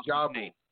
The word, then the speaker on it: Ajabu: And I look at Reverend, I don't know how to pronounce his name. Maybe you know how to pronounce Ajabu. – His name. – Reverend Emoja Ajabu.